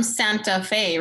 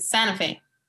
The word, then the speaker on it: Santa Fe: I don't pronounce T's like I'm from Santa Fe, right? Santa Fe. We don't say the T.